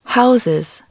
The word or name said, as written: houses